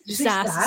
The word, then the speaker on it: SaaS: SaaS. How do you say that? – SaaS, software as a service.